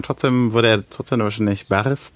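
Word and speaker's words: Baris (Борис): O zu spricht man A aus? – O spricht man A aus, ja. Okay, das äh, Also Boris heißt dann Baris.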